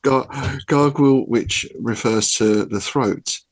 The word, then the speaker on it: Gargouille: See, I started off strong with the French pronunciation. Now it's all downhill. Gargouille.